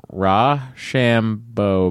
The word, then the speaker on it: Rochambeau: It's pronounced Rochambeau baby, as in rock, paper, scissors, and their awesome sunglasses.